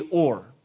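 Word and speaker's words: or (אור): But in Hebrew, it says, in the beginning, God said, let there be or. And that's the Hebrew word for light. O-W-R is kind of how you would pronounce it in English.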